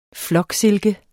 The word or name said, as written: floksilke